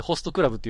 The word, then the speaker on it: ホスト部: ホスト部って書いて、ホストクラブって読ませるんです、これ。